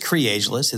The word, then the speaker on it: Creageless: It is Creageless. It's kind of tricky to spell. And when you see it, it's kind of tricky to pronounce, but it's basically creativity is ageless.